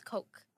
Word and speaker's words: Koch: How do you pronounce that? – That's Coke.